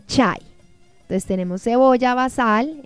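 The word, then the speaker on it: basal (بصل): Cebolla se pronuncia basal.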